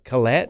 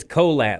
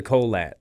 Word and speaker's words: Kolat: And I'm like, did I say Colette? – Yeah. – It's Colat. Like, where's the Colat? That's how Carrie Colat's wife told me how to say it. She goes, I've been married to Carrie for 15 years before I knew how to pronounce his name, and he would never correct me. And then someone was like, it's like, where's the Colat? So Colat, K-O-L-A-T.